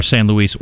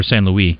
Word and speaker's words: San Luis: Or San Luis or San Luis, depending on how you like to pronounce.